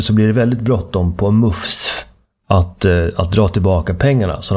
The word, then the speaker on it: MUCF: Och det här resulterat då i att Myndigheten för ungdoms- och civilsamhällesfrågor, MUFS, M-U-C-F, det skrivs ofta i tidningsartiklarna med små bokstäver, vilket antyder att man ska uttala det MUFS,